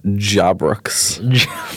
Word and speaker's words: Jabbrrbox: So phonetically, that's actually just Jabbrrbox.